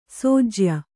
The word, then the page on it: ಸೋಜ್ಯ: Kannada